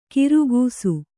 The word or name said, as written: ಖರ್ಗಸ